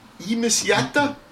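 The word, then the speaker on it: Ihmisjäte: Ihmisjate.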